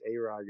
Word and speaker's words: Arye: Like, it's whatever, but it is Arye, guys.